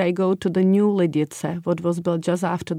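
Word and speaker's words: Lidice: So we pronounce it as Ladice. – Ladice. – Ladice, because there is the ts sound.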